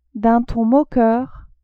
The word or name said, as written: d'un ton moqueur